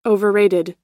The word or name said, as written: overrated